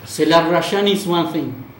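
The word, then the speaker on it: Zelarayán: If you're either an armchair scout or a football manager player, the surname is spelled Z-E-L-A-R-A-Y-A-N. and it's pronounced in Argentine Spanish, Celarayán.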